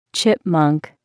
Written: chipmunk

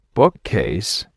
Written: bookcase

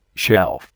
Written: shelf

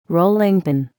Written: rolling pin

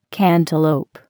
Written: cantaloupe

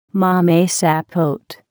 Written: mamey sapote

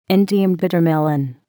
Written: Indian bitter melon

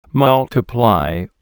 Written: multiply

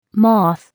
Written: moth

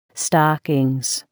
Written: stockings

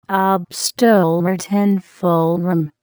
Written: obsttortenform

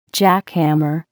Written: jackhammer

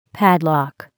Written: padlock

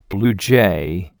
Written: blue jay

